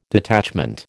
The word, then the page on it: detachment: American English